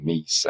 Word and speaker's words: Meis: So phonetically, it should be mice, but somewhere along my family line, they decided they didn't like that. And so they started saying meese, and it, has been that way ever since.